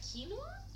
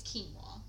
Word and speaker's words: quinoa: Quinoa? – It's quinoa. – I used to say quinoa. – Yeah, well that's the incorrect English pronunciation.